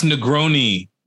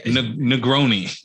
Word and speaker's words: Negroni: It's pronounced Negroni. – Yeah. – Negroni.